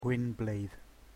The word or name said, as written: gwyn bleidd